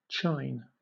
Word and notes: chine: British English

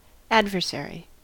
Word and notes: adversary: American English

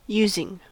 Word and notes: using: American English

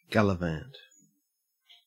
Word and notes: gallivant: Australian English

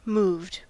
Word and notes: moved: American English

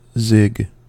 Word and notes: zig: American English

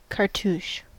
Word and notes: cartouche: American English

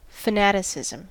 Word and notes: fanaticism: American English

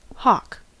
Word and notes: hawk: American English

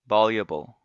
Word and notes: voluble: American English

